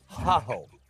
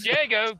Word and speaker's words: Jago: Well, I thought it was pronounced ha ho. – No. We're in America. The J is pronounced J, not H. It's Jago.